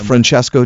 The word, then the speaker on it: Francesco: Francesco Di Cosmo.